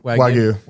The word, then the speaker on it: Wagyu: Wagyu.